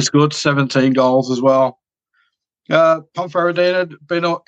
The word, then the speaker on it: Ponferradina: And it's Ibiza against Ponferradina. I hope I'm saying that right. – Oh, you are. Yeah, that's good pronunciation.